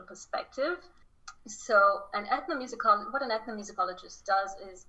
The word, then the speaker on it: ethnomusicologist: So you are a, I'm probably going to say this wrong, ethnomusicologist.